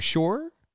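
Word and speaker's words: Shure: You said Sure or Shura? – Shura or sure, Sure. I always said sure. That's how I've always said it.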